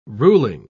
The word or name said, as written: ruling